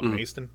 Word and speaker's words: Macedon: Which is spelled like Macedon. It's Macedon, but they call it Maston.